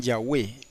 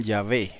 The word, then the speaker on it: Yahweh: Por eso es que si ellos fueran a pronunciar el nombre, si fueran, porque no lo hacen, dirían Yahweh, mientras que los demás judíos dirían Yahvé, si lo fueran a pronunciar.